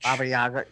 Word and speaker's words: Baba Yaga: Baba Yaga.